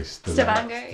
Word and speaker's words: Stavanger: Stavanger.